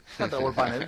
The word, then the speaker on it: Kantar Worldpanel: Estamos hablando con Managing Director del Cono Sur de Kantar Worldpanel. ¿Lo dije bien? – Kantar Worldpanel, está bien.